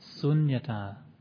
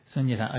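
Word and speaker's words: śūnyatā: Sunyata. Shh is in Sanskrit. That's the important thing, is getting the pronunciation right. Sunyata, okay.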